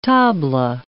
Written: tabla

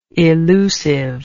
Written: elusiveness

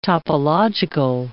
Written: topological

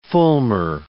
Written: fulmar

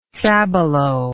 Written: sabalo

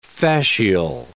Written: fascial

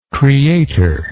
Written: creatorship

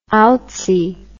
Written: outsy

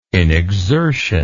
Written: inexertion

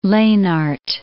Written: Lenard

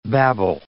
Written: babble

